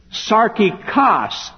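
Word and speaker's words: Sarkikos (σαρκικός): S-A-R-K-I-N-O-S. In the third verse, there's another word for carnal that is almost like that. But that word is spelled like this. Sarkikos, S-A-R-K-I-K-O-S. Sarkinos, sarkikos. Two different words.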